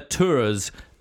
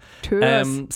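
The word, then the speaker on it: Törzs: Emma Touras. And I'm just going by the audiobook narrator's pronunciation of that name. But it's a T-U-R-Z-S. – Oh, it's like an U? – Like an umlaut O thing there.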